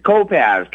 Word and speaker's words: Kopacz: It's Kopasz, K-O-P-A-C-Z, and the C is okay.